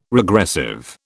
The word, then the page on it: regressive: British English